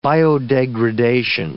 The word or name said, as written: biodegradation